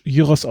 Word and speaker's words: Gyros: Und aus Griechenland, also Shawarma ist dann mit Rindfleisch und aus Griechenland gibt es dann den Gyros, oder wir kennen als Gyros, aber neugriechisch Gyros ausgesprochen, mit Schweinefleisch.